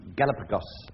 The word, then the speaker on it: Galapagos: It was considered long extinct in the Galapagos. That's the islands. That's the correct pronunciation, by the way.